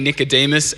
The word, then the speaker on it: Nicodemus: It's quite a conversation between Nicodemus and Jesus.